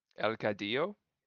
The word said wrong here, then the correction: Cadejo: Cadejo. – I'm sorry. – El Cadejo.